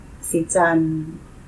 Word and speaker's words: Sitan: And I just like the fact that Sitan is our way of pronouncing it. And if you're not Thai, you wouldn't pronounce it Sijan because it's clearly, you know, spelled a certain way.